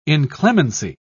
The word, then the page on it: inclemency: British English